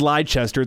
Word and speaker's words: Leicester: It's spelled L-E-I-C-E-S. – Yeah, see, I'm an idiot, but that's. – It's spelled Leicester.